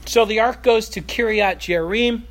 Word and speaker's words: Kiriath: So I'm pronouncing this as Kiriath because that's really closer to how it is in Hebrew. Hebrew really doesn't have a J sound.